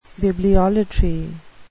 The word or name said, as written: bibliolatry